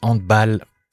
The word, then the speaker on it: handball: Mais il y a quand même cette petite particularité, en français, on prononce football à l'anglaise et handball à l'allemande.